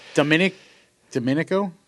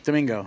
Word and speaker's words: Domingo: Domenico? – Sabato Domingo. – Domingo.